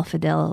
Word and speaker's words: fidil: And actually, since we're nerding out on the word, the Irish word for the instrument is spelled F-I-D-I-L, fiddle.